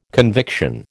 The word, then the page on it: conviction: American English